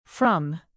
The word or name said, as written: from